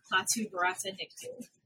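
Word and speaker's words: Klaatu barada nikto: The incantation, Klaatu Barata Nictu. – Oh, you say it correctly.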